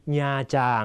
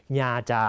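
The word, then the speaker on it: Nha Trang: ับเช่นแอมกุ๋งหอกเตียงเวียดเออได้หอกเกษตรศาสตร์นะครับน้องก็เรียนภาษาเวียดนามที่มหาวิทยาลัยเกษตรศาสตร์นะฮะหรืออีกตัวอย่างโบกัวแอมกุ๋งทิกดีญาจางนะครับพ่อของน้องก็ชอบไปญาจางคำนี้ต้องอ่านให้ถูกนะฮะอ่านว่าญาจาง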